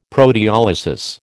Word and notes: proteolysis: American English

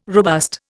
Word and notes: robust: Indian

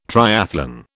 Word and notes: triathlon: British English

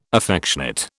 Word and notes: affectionate: British English

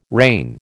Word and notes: rain: American English

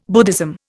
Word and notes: Buddhism: Indian

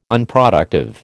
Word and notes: Unproductive: American English

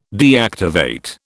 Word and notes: deactivate: British English

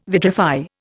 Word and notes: vitrify: Indian

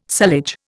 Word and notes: sullage: Indian

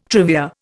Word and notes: trivia: Indian

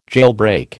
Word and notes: jailbreak: American English

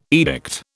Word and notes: edict: British English

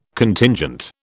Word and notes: Contingent: British English

